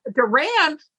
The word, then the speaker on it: Doran: And I was blessed to have a really awesome graphic artist you may have met, Jim Doran, I'm going to say, help me out with Duran. – Oh, shit. See, I don't even know how it's pronounced. It's writing. – Like Duran Duran.